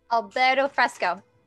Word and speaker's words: Alberto Fresco: Alberti Freschetti? – Hold on, I got it. – Alberti Freschetti or something like that. – Alberto Fresco. – Yeah. That sounds right.